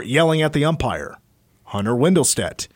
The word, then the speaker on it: Windelstedt: I'm going to go with MLB umpire Hunter Windelstedt. Am I saying that right? – Yes. – Windelstedt.